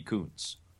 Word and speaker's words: Kuntz: I'll spell Kuntz. It for you. It's K-U-N-T-Z. Kuntz, is that correct?